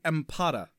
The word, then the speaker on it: Ampata: Empata. – Say that one more time. – Empata. – Empata. – I had to look it up on IMDb. It's spelled A-M-P-A-T-A.